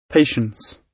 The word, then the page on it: patience: British English